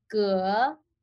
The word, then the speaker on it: Ge: So my first name is Luna, and my last name is actually Ge, as like the third tone of Chinese. I just get tired of like correcting people.